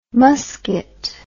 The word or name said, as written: musquat